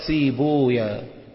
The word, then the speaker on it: سيبويه: وكان الخليل بن أحمد ينطقها سيبويا ويقول اسم ويه اسم شيطان، يعني كان بيقول كده يقول ويه اسم شيطان، فلا تقولون سيبويه وخمار ويه، وإنما ضموا اللفظ فقولوا سيبويا وخمارويا، هكذا كان يقول الخليل بن أحمد.